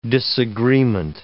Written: disagreement

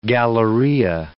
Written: galleria

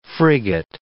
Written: frigate

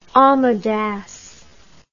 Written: amedas

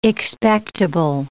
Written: expectable

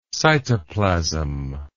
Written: cytoplasm

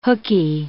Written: hookey

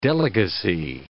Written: delegacy

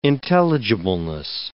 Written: intelligibleness

